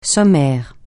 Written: sommaire

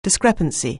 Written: discrepancy

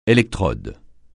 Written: électrode

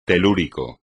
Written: telúrico

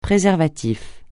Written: préservatif

